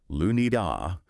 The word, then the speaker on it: Lunea: Now, at Lunea, that's L-U-N-E-A, pronounced Lunida, we're, you know, deeply committed to transparency and to education and supporting ethically sound research in this whole area.